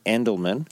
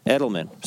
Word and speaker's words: Edelman: And there's a company that started in 1952 by a guy named Dan Edelman. – Edelman. – Sorry, I put an N in there. Don't trust me.